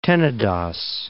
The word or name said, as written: Tenedos